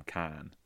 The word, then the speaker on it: Cannes: Like, they bought two films from Cannes. You know, that. – Cannes. – Cannes Film Festival. – Oh, I always saw Cannes. – It's a silent S.